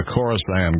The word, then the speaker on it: Khorasan: K-H-O-R-A-S-A-N, Khorasan.